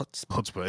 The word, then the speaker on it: chutzpah: I hope I'm pronouncing this right. Chutzpah. – That's really how it's pronounced. Chutzpah.